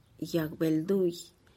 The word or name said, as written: yag-belduih